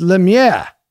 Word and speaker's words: Lemieux: You're mispronouncing Lemieux. It's Lemieux.